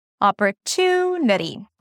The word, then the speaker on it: opportunity: I go higher in pitch for the primary stress, opportunity.